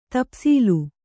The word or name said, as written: ತಪ್ಸೀಲು